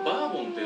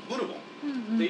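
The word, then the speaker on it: Bourbon: 勝った印を残したいので、うんうん、ちょっとお名前頂戴できませんかね、うん、ブルボンさん言ってお名前を頂戴して、うん、ケンタッキー州にあるある軍部にそのバーボン軍という名前、うん、実はこのバーボンっていうのはそのフランスのブルボンっていう単語を英語読みにすると、うんうん、バーボンって読めるんですね、綴りは一緒です、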